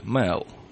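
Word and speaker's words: Mael: И фамилия у них произносится так с долгим «э» — Мэл. Вот что-то по-русски, наверное, будет Мэл. Так и будет.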